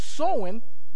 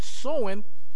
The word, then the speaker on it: Samhain: And they worship the Lord of Death, or the Lord of the Dead, or Samhain, which pronounced Samhain, that's how we pronounce it in the occult, Samhain, in October 31st.